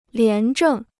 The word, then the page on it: 廉政: lián zhèng